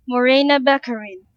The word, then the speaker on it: Morena Baccarin: He said Marina Baccarin when it's actually pronounced Marina Baccarin. – Morena Baccarin.